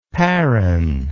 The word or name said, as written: parran